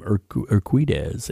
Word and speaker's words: Urquidez: David Lee Roth dedicated the song to martial arts legend Benny the Jet Enriquez. Uh, I can never say his name, Urquidez.